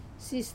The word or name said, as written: sis